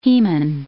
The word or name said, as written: hemin